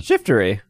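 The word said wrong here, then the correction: Shiftry: It's two syllables, not three. Shift tree. – Shifter- You kept saying shiftery.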